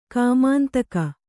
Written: ಕಾಮಾಂತಕ

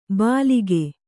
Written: ಬಾಲಿಕೆ

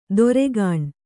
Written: ದೊರೆಗಾಣ್

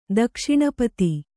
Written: ದಕ್ಷಿಣಪತಿ

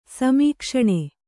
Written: ಸಮೀಕ್ಷಣ

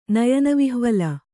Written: ನಯನವಿಹ್ವಲ

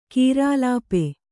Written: ಕೀರಾಲಾಪೆ

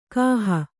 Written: ಗೊಹೆ

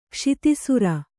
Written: ಕ್ಷಿತಿಸುರ

